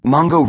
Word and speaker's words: Mongo: Eliot named a mischievous cat Mungo Jerry, but nobody ever called him Mungo for short, and there's a whole vowels difference between Mungo and Mongo anyway. Mongo rhymes with bongo, congo, longo, and pongo, none of which sounds like run-of-the-mill English, with good reason.